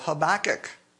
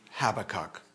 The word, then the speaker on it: Habakkuk: Turn to the book of Habakkuk, or again, Habakkuk, depending on your pronunciation.